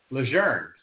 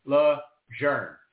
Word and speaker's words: Lejeune: But then I heard an interview with his widow, and she pronounced it Lejeune. So I'm with that. They actually had a campaign down there in North Carolina. They had license plates that said, say Lejeune.